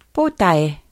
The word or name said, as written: potae